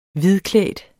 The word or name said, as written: hvidklædt